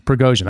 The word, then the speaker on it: Prigozhin: I say there Prigozhin. – Prigozhin, that's right.